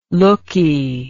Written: lookee

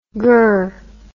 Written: grr

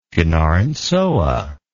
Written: Fianarantsoa

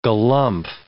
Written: galumph

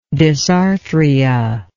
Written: dysarthric

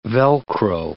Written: Velcro

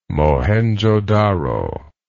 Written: Mohenjo-daro

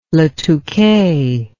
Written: Le Touquet

